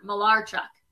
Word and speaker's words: Malarchuk: And Jake, make sure you correct me if I'm pronouncing his name wrong, but Clint Malarchuk. – Yeah, you got it.